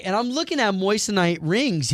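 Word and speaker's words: moissanite: No, you're not moistenite. Saying it right. It's moistenite.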